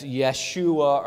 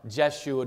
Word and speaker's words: Yeshua: But his name he starts off with is Jesus, so that's Yeshua or Jeshua, depending on how you want to pronounce it.